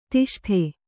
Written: ದಷ್ಟ

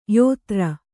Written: ಯೋತ್ರ

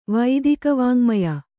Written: ವೈದಿಕವಾಙ್ಮಯ

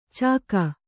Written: ಚಿಕ್ಕೆ

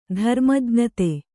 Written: ಧರ್ಮಜ್ಞತೆ

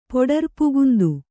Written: ಪೊಡರ್ಪುಗುಂದು

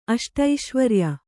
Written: ಅಷ್ಟೈಶ್ವರ್ಯ

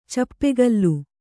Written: ಚೂಪುಗೋಲು

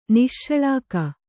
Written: ನಿಶ್ಶಳಾಕ

